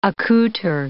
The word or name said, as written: accouter